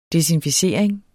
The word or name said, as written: desinficering